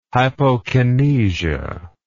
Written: hypokinesia

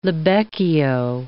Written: libecchio

